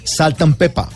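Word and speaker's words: Salt-N-Pepa: Sí, se escribe con, con ah. N intermedia, pero no dice ant, sino ah, saltan pepa.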